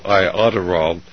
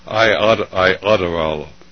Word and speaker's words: Iodoral: Well, I think there's a compound called iodorol, which, of course, has both of them in it. – Iodol? – Iodorol.